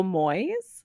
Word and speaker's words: Moyes: Jojo Moyes, M-O-Y-E-S.